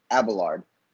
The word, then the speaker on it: Abelard: Abelard.